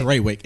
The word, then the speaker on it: Trawick: Nick Trawick. Trawick. It rhymes.